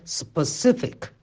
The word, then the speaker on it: specific: 第一呢，重音不在 spec 这个音节上，而是在 c 这个音节上。然后呢，第一个元音呢，不是读 a，是读 a，对吧？所以呢，这个单词实际上应该读作 specific。